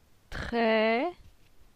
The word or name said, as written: Tre